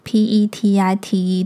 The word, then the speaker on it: Petite: 我之前呢一直以为我的品牌是念成 The Petite Elsie，然后我今天不知道哪根筋想到，就是我去搜寻一下 Petite P E T I T E 的正确发音，结果我发现它的正确发音是 Petite。